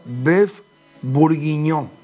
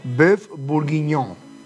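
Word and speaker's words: bœuf bourguignon: El primero se pronuncia Beef Bourguignon, con nasal al final tipo guaraní, me dice. Beef Bourguignon.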